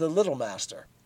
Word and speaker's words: Little Master: the Little Master. You must pronounce this all as one word with the accent on the first syllable, like Jerry Builder.